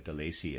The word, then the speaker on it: Delaisi: I'm pronouncing that De Lacy. It's spelled D-E-L-A-I-S-I, Reader's Aside.